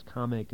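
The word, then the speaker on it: Commack: I might point out there'll be some debate as to the pronunciation of the town you're about to say, which is Comac. – Comac, right. – Well, some people say it's pronounced differently. Some people say it's Comic.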